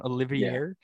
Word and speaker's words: Oliveira: Is that how you say his name? Oliveira. Yeah.